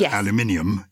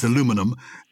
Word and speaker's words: aluminium: So it's spelt like magnesium and it's pronounced aluminium in the UK. – Yes. – But in America, it's aluminum and it's spelt like platinum.